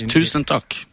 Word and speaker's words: Tusen takk: How do you say that? – Thank you very much. Tusen takk.